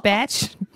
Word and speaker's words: Cumberbatch: So you are very into Benedict Cumberbatch. Did I say that right? – No. – Batch. – Oh my God. Like Johann Sebastian Cumberbatch?